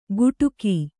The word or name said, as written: ಗುಟುಕೀ